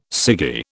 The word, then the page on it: ciggy: British English